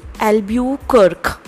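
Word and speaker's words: Albuquerque: ये बहुत ही खतरनाक सा स्पेलिंग है इसका ये भी स्पेल कर रही हूँ A L B U Q U E R Q U ई एल्ब्यू कर्क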